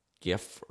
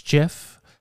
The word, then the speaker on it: GIF: Actually GIF. – GIF, yeah. – But according to the guy who invented it, it's GIF, like the peanut butter.